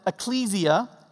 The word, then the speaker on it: ekklesia: Literally, ekklesia, or we could also pronounce it ekklesia.